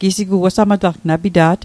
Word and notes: apita't: Mi'gmaq

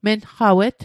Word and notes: menqawet: Mi'gmaq

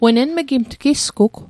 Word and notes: enmigimatl: Mi'gmaq

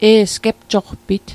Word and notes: gepjoqpit: Mi'gmaq

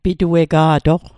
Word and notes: pituega'toq: Mi'gmaq

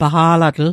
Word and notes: paqa'latl: Mi'gmaq

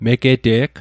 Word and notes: megete'g: Mi'gmaq

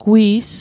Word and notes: gwi's: Mi'gmaq

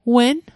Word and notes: wen: Mi'gmaq

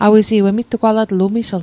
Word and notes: emittugwalatl: Mi'gmaq

